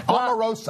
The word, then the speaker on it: Omarosa: Ann taught me how to pronounce her name properly on the way up here today. Omarosa.